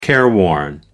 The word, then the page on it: careworn: American English